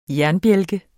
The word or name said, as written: jernbjælke